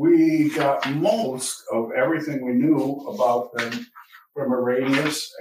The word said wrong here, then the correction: Irenaeus: Hippolytus and Irenaeus.